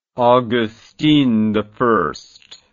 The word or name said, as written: agustini